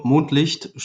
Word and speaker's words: Mondlicht: Our studio, Mondlicht Studios, it's German pronunciation